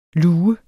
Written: luge

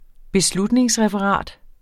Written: beslutningsreferat

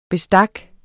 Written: bestik

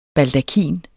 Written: baldakin

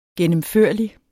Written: gennemførlig

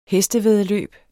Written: hestevæddeløb

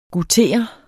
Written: goutere

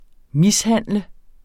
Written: mishandler

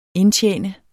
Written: indtjene

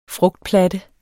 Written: frugtplatte

